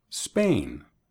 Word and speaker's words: Spain: No Spain, sino Spain. Spain. Hay una tendencia para los hispanohablantes agregar una E al principio de las palabras inglesas que empiezan con S. Quieren decir Spain, no Spain. Spain.